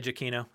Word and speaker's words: Giacchino: How do you say his name? You would know better than anyone. I just say Giacchino.